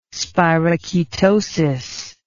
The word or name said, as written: spirochetosis